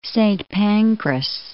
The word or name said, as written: Saint Pancras